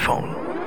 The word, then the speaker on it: Phaune: It's spelled P-H-A-U-N-E, but it's pronounced phone.